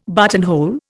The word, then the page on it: buttonhole: Indian